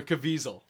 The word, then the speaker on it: Caviezel: Superman is going to be played by JC, Jim Caviezel. – Oh, wow. Really? – Or Caviezel, as how right. he's pronouncing it nowadays.